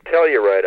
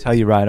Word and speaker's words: Telluride: And do you pronounce it Telluride? – Yeah, Telluride, I believe. – Telluride, okay.